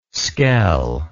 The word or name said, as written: skell